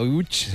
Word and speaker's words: Łódź: Aunque se pronuncia Łódź, se escribe Lodz, por si alguien lo busca. – Lodz, Google. Casi no tiene nada que ver la pronunciación.